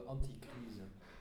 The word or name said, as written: anti crise